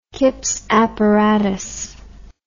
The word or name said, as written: Kipps apparatus